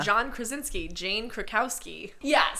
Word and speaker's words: Krakowski: Jane Krasowski. I love them all. Well, yeah. However you say her name. – Jane Krakowski. – Or Krasowski, like I said. – You're thinking of John Krasinski.